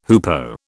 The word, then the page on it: hoopoe: British English